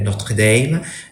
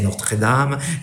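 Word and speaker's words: Notre Dame: Là, j'ai été visiting professeur à l'université de Notre-Dame. J'utilise la prononciation américaine, pas français Notre-Dame.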